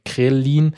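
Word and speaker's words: Ghrelin: Das haben wir jetzt vorher auch ein bisschen mitbekommen bei äh, Leptin und Krela. oh Gott, ich kann es kaum aussprechen. Krelin.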